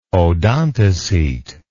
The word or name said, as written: odontocete